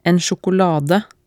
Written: en sjokolade

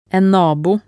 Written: en nabo

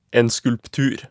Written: en skulptur

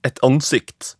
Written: et ansikt

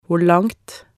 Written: hvor langt

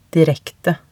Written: direkte

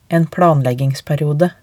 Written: en planleggingsperiode

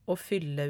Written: aa fylle

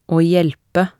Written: aa hjelpe

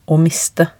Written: å miste

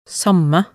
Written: samme

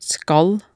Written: skal

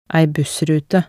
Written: ei bussrute

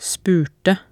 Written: spurte

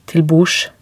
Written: til bords

